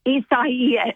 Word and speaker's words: Isaias: That's Isaias, but I think it's the Spanish pronunciation. So it's like Isaias.